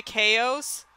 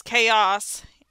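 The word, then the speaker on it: KOS: Chaos. Actually, it's pronounced Chaos. It's spelled K-OS, and I realized, and like, and then I Oh.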